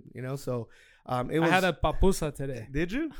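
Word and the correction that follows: pupusa: Yeah, it's good from is that how you say it? No, popusa.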